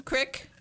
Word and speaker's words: crick: And is that the way you pronounce the crick? – Yeah, Fork in the Crick. Yep.